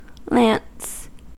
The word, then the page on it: lance: American English